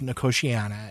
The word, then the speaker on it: Nicotiana: It's spelled like nicotine with an I-A-N-A at the end, but pronounced Nicotiana.